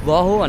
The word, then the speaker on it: Rahul: Rahul. Unless I put on an Indian accent, I can't pronounce it properly.